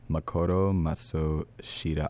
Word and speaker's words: Makoto Matsushita: and it's Makoto Matsushita.